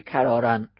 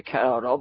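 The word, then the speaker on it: کراراً: همونجوری که آقای محسنی تلفظ فرمودن، تلفظش میشه کرارن. اما یک دانشجوی دیگه، دانشجوی رشته دیگه، ممکن اینه کرارا بخونه و هیچ وقت متوجه نشه این معنیش چیه.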